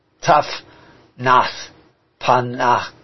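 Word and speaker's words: Zaphnath-Paaneah: So Joseph gets this name, Zephanat-Paaneah, which is actually what you see when it's spelled in English is a Z at the beginning, but in pronunciation it's more like the Tzedek at the beginning, or maybe more like the Tet at the beginning. But so it's like Tufnath-Paaneah, and the Ak at the end is the symbol of life.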